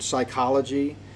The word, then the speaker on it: psychology: Soul is psuche, where we get the word psychology. In English, the P is silent, but in Greek it's not.